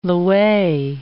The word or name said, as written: lwei